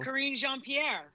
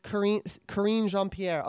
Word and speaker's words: Karine Jean-Pierre: Karine Jean-Pierre. – Karine Jean-Pierre.